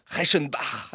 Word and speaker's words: Reichenbach: Comment vous prononcez le suisse ? – Reichenbach. – Ah oui, c'est bien.